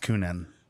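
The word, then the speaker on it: Kuhnhenn: We've got the Dragon's Milk Solera and the, I don't even know how to pronounce Kunin Brewing? – I think it's just Kunin. – Kunin Brewing? – Because K-U-H-N is Kun, and then it's Hen, H-E-N, Kunin. And so Kunin.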